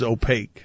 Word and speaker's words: opaque: Man, it would have sounded like you weren't reading that at all if you had actually pronounced it the way it should be pronounced, which is opaque.